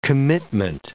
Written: commitment

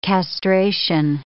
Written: castration

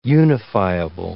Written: unifiable

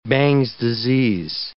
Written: Bang's disease

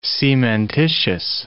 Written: cementitious

